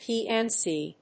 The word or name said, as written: PNC